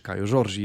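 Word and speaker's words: Kaio Jorge: A do Frozinone przeszli Matias Sule, i Kajo George, e, czy Kajo Giorgi, jeżeli wolicie. Julek mówi, że tak się powinno to wymawiać, e, z Juventusu.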